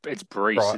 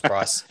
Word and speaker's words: Breece: Is it Bryce or Breece? – It's Breece. It's a cracking name, eh? Oh, Breece. – I thought it was Bryce. – It's spelt Breece, and I'm going to say it Breece.